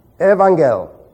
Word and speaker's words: Evangel: Double gammas in the Greek language are pronounced ng. Let's transliterate it. E-V-A-N-G-ng, here it is, E-L. We'll leave this off. That's Greek. Evangel.